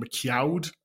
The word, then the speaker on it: McQuoid: And we were lucky enough to chat to the new Sub-Zero, who's played by Joe Taslim, and also the movie's director, Simon McQuoid. I think I'm pronouncing that correctly. It's McQuoid.